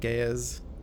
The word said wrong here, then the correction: geis: Gaia's? The Geis. – The Geis, yeah.